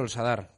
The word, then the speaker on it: Sadar: Eh, ¿El Sadar o el Sadar? Que tú eres muy puntilloso para esto. El Sadar. Ah, el Sadar, vale, pues así lo digo bien toda la semana.